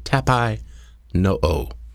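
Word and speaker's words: tapainoo (ταπεινόω): The Greek word for humility is pronounced tapainoo. Tapainoo.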